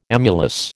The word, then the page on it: emulous: American English